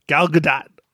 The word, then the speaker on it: Gal Gadot: How do you say it? – Gal Gadot. – Yeah.